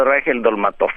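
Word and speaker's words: Reichel-Dolmatoff: Questo testo è scritto, ecco, io leggo qua, non vorrei sbagliare, Gerardo Reichel Dolmatov, è giusta come pronuncia? – Gerardo Reichel Dolmatov.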